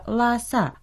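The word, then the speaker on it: ла-са (拉萨): По-китайски это название произносится «ла-са», «ла» произносится в первом тоне, а «са» читается в четвертом тоне.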